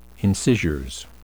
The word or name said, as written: incisures